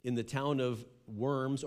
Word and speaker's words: Worms: how in English it's spelled out, the diet of worms, okay?